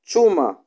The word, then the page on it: chuma: Kiswahili